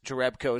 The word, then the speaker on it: Jerebko: It's Jonas Jerebko. is the pronunciation uh, according to Basketball Reference.